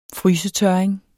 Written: frysetørring

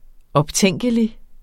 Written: optænkelig

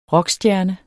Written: rockstjerne

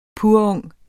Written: purung

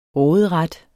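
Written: RÅDERETS

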